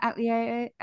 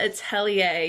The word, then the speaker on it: Atelier: Well, should we go into a little description of what Witch Hat Atelier, I said it wrong again. – The Witch Hat Atelier, yes.